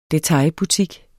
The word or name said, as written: detailbutik